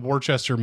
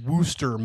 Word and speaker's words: Worcester: Well, so Gavin found a place, I don't know exactly where, but in Worcester, Massachusetts, Worcester, Mass. – Worcester, Mass.